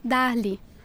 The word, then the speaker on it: Darly: Darley. – How do you spell that? – D-A-R-L-Y. – D-A-R-L-Y? – Yes, that's right.